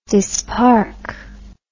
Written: dispark

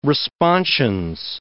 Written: responsions

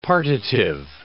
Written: partitive

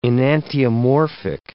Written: enantiomorphic

